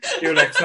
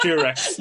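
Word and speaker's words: Dulux: Dulex, not Durex.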